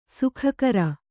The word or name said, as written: ಸೋಗುಗಾರ